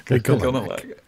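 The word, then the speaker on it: Y'golonac: That when you look through it, you see reality, and it has this kind of corrupting influence of Igolanak. Igonalak. I never say that right. Which way is it? Igolanak. – Igolanak. – Igolanak. L first, N second.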